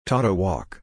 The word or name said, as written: taught to walk